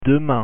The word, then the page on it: demain: French